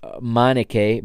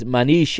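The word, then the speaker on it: Manniche: New signings, Claudio Adão and Michael Manish, or Manik, Michael, I believe the way it's the English commentators pronounce it is Michael Manike, but it is Manish in Portuguese, or Manik to some.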